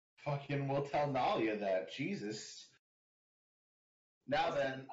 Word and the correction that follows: Nyla: I don't think that's how you say her name. – Nyla. – Nyla. – That. Words. – Man, I totally transposed in my head the I and the L.